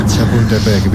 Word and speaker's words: Chapultepec: Chapultepec. Mejor que algún cementerio francés. – ¿Dígles? Chapultepec, bien, bien, bien.